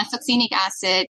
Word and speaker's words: succinic acid: I feel like I always read succinic acid. Am I pronouncing that correctly? – Yeah, succinic acid.